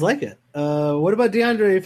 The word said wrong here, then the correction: De'Aaron: Fox? – De'Aaron Fox? – Ooh. De'Aaron Fox. I always get that name wrong.